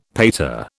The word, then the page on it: pater: British English